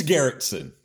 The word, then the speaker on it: Garretson: It's Garretson.